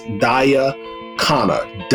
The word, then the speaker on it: Khanna: I think her name is pronounced Daya Khanna.